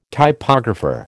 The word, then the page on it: typographer: American English